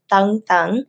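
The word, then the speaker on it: Tân Tân: The name Tan Tan in Vietnamese is pronounced Thung Thung, and Thung Thung means new new.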